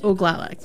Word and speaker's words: Oglala: And the Two-Spirit is one part of your identity, and Oglala, did I say that correctly? – Oglala, correct.